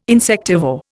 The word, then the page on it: insectivore: Indian